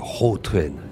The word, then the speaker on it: Gautrain: on aurait tendance à prononcer Gautrain et le Rautrain.